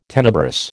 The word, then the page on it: tenebrous: American English